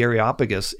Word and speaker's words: Areopagus: And so he goes up the Areopagus, and he takes a different tack here.